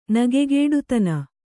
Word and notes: ನಿಗ್ಗೆಡಿತನ: kannada

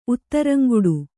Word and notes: ಉತ್ತರಂಗೆಡು: kannada

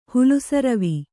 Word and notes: ಹುಲುಸರವಿ: kannada

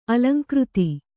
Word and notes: ಅಲಂಕೃತಿ: alaŋkřti

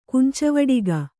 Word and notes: ಕಂಚುವಡಿಗ: kancuvaḍiga

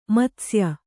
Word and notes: ಮತ್ಸ್ಯ: matsya

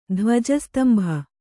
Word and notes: ಧ್ವಜಸ್ತಂಭ: dhvaja stambha

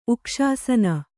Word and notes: ಉಕ್ಷಾಸನ: kannada

